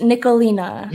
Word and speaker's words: Nicholena: It's Nicholena.